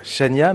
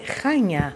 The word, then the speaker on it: Chania: Si on prononce à la française, je, disais, on, a tendance à dire Chania, mais ça c'est très mal prononcé. – C'est très mal prononcé, c'est Chania.